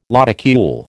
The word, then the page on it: lodicule: American English